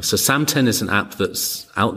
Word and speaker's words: Samten: I've got a mindfulness app that's called Samten. – Samten. Can we spell that? – S-A-M-T-E-N. Sam like Sam, the name, and ten as in number 10.